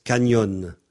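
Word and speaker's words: cañón: Alors, on écrit C-A-N tilde O-N. Et on pense canyon. Mais quelquefois, en français, on écrit C-A-N-Y-O-N. Autrement dit, le N avec un tilde dessus, un tilde, se prononce nye, comme en français, le G-N.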